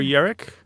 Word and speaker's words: yarak: Alas, poor Yerrick? – No, this is spelled Y-A-R-A-K. Yerrick.